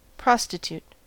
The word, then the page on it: prostitute: American English